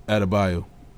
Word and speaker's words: Adebayo: What's his last name you pronounce? – Bam Adebayo.